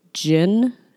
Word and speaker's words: Djinn: One that really intrigued me was called, and I'm probably going to mispronounce it, it's called the Jin, D-J-I-N-N, yeah. – Yeah. – Jin, it's like, it's the root of the genie. It's a genie, basically. That's what a jin is.